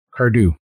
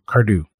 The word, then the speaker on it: Cardhu: Cardew. – Cardew? – Cardon't. – C-A-R-D-H-U. Cardew.